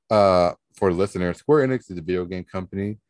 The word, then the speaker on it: Square Enix: Square Enix.